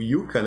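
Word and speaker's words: Jukka: O Yuka, né? Do Nightwish. O batera original Nightwish, que não tá mais, né? – Juka. – É, Yuka. Se escreve Juka.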